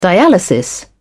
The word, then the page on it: dialysis: British English